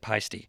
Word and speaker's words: Paiste: And Paiste, that's how you pronounce it, Paiste.